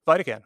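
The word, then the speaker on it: Vidacann: Also on that list with the Vidacan, I always forget how to say it. Is it Vidacan? Mm-hmm. – Vidacan, okay. Like vitamin.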